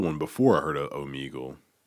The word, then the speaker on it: Omegle: Meagle. – Omegle.